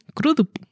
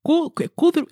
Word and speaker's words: Crudup: ¿Cómo se pronuncia? Nunca lo sé. Crudup. Kudrup, es que es Kru.